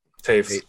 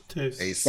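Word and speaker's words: Toews: Toes, Theus. Wie spricht man aus? Ihr wisst, wen ich meine. – Taves. Taves.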